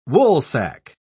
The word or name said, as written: woolsack